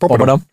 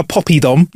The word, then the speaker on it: poppadom: That's how you pronounce it, poppadom. – You can't not say it right. – Poppadom. – Yeah, I'll have a poppy-dom. Nah, mate, there's no Y in it. It's poppadom.